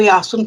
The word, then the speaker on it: Jáson: A taky se nevyslovuje jako s tím hebrejským způsobem, ale vyslovuje se jako Jáson, píše se I-O, jo, jako tím, tou výslovností tak, jak je, tak to I-O se čte jako já, tak jako se třeba v Byzancii a podobně tímto způsobem se mluvilo, to jsou poloslovanský, slovanský řeči.